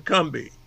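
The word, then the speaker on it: Combahee: Let's use the word that and pronounce it the way the folks down that way. So three small ships left Beaufort and headed for the Cumbie.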